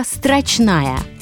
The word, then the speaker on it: строчная: Вы уже услышали, с каким ударением произношу слово «строчная»? С ударением на последнем слоге. Именно с таким ударением «строчная» на последнем слоге и рекомендуют произносить это слово в строгой речи.